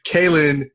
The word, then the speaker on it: Kalyn: Kalyn Josephson. Okay, got it. All right. See, I didn't do it too bad. I'm horrible with names. – It's the three syllables that throws people off.